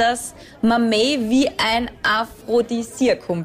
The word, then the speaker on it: mamey: Mamey.